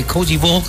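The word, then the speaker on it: Côte d'Ivoire: Ya hay alineaciones por parte de Alemania ante Cozy Vogue. Así se pronuncia en François.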